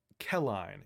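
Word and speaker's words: Qelline: it's actually Kelline is what I'm going to say. It's Q-E-L-L-I-N-E.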